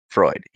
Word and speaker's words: Freud: But they're dealing with, historical figures, like Sigmund Freud? How do you say it? Freud, yeah.